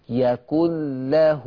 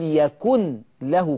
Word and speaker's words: يكن له: عايز تقول يا شيخ ان الحرف الخالي من التشكيل ورا منه حرف مشدد، اذن المنطوق هو الحرف المشدد، يعني مش هنطق النون ولكن تنطق اللام، ياكل ياكل ياكل. سامعين صوتي؟ ياكل له كفوا احد. لان في ناس عندها ظن ان كل ما كتب في القرآن ينطق. ناس فاكره عشان شايفه حرف النون تقول يكن له كفوا.